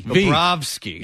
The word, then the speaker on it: Bobrovsky: Bobrovsky with a V. – Bobrovsky.